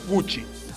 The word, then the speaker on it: Gucci: que Gucci se pronuncia Gucci.